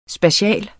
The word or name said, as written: SPATIALT